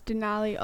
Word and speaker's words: Denali: Yes, it's Denali, also known as Mount McKinley.